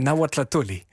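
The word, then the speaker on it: Nahuatlahtolli: Nahuatlatoli. – Ja? – Ja, zo spreek je het uit.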